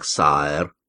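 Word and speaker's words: Sire: В Англии короля называют сайр, произносится именно так, сайр.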